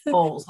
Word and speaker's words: Bowles: Bowles, like a cereal bowl.